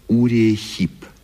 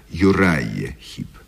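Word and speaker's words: Uriah Heep: Нет-нет, а в письмах юных поклонников рок-музыки повторяется просьба: расскажите о группе Урия Хип. По-английски произносится Юрайя Хип.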